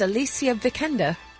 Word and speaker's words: Alicia Vikander: I said Alicia Vikander. And right. – She said, this is Alicia Vikander. – Mm. All right. – And not in that accent at all, either.